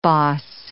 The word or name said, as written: boss